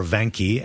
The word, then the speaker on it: Vanke: Wanke. – Yeah. 2202. – Yeah, 2202. China Wanke, or Vanke, as it's spelled, V-A-N-K-E.